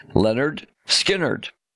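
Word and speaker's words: Lynyrd Skynyrd: The title of the album was a pronunciation guide because nobody could figure out how to say the band's name. Pronounced Leonard Skinnerd.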